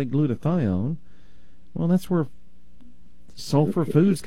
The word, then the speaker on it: glutathione: Glutathione.